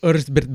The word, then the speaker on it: Erzsébet: Countess Dracula herself, Mrs. Elizabeth Bathory, or as they say in Hungary, Bathory Erzsébet. – Erzsébet Bathory. – There you go. Thank you, for coming through with the correct Hungarian pronunciation.